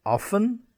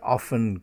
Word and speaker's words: often: And this word, which you can pronounce both ways, often, I often go home at the weekend, or often, how often do you have a glass of wine? Often, often. Both acceptable.